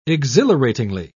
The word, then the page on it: exhilaratingly: British English